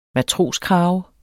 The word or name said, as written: MATROSKRAVER